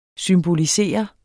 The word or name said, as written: SYMBOLISER